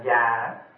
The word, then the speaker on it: già: Chữ vô giá đây không phải là gọi là quý báu, không có gì giá trị hơn nữa, mà chữ giá đây cũng đọc là già, giá hay già tức là ngăn,